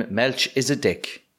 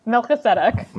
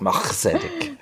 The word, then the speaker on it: Melchizedek: And I'm going to admit that I've been calling him Melch is a dick. Because his name is spelled Melch is a dick. – It is. – And I did not know that that was not how you were meant to pronounce that. So Omar Sharif, God rest your soul, you did not play a character called Melch is a dick. – He doesn't. Melchizedek.